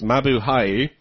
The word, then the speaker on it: Mabuhay: Mabuhay, our native language, pronounced Mabuhay, which means greetings, to the humorous yet sensible guys at Lug Radio.